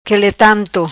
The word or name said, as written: Quele Tanto